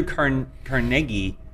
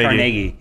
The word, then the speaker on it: Carnegie: I think it's Carnegie. Carnegie. Yeah. – Oh. – And everyone goes Carnegie Hall, but it's actually Carnegie.